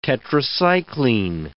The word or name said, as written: tetracycline